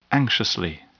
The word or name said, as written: anxiously